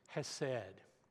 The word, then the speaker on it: hesed: But there's a word that goes alongside of that, which is the word, Hebrew word for kindness, which is hesed. That's the English rendering of that. To say it properly, you gotta like throw a lot of guttural sort of like stuff in the back of your throat.